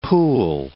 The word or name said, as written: pool